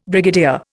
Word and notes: brigadier: Indian

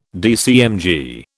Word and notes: DCMG: British English

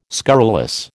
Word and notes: scurrilous: American English

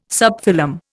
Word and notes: subphylum: Indian

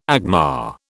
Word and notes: agma: British English

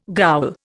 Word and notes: ghoul: Indian

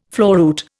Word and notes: floruit: Indian

